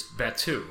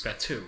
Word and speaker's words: Batuu: Is Batuu, right? – It is. – Batuu.